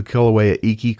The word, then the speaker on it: Kilauea Iki: The Kilauea Iki Crater. – Yes, we really liked that hike. – Yeah, it takes you 400 feet into the Kilauea Iki Crater.